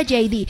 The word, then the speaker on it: Heidi: Se escribe Heidi, se pronuncia JD.